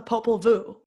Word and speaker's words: Popol Vuh: I'm going to tell you about the Popol Vuh. Have you heard of the Popol Vuh? – Sounds like a type of bug. – It does, kind of. Vuh is spelled V-U-H, but it's pronounced like V-O-O.